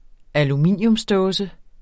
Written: aluminiumsdåse

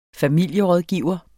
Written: familierådgiver

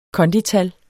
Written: kondital

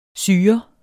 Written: syrer

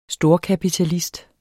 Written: STORKAPITALIST

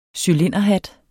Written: cylinderhat

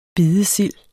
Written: bidesild

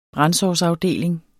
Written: brandsårsafdeling

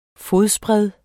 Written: fodsbred

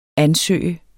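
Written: ansøger

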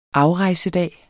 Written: afrejsedag

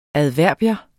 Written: adverbium

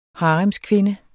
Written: haremskvinde

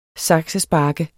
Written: saksespark

